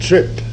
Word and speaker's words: trippe: They used to do that in the pork sausage too, that they called trip. T-R-I-P. The meat markets spell it T-R-I-P-P-E. Of course, they're not Belgians. But it sounds just like trip, T-R-I-P.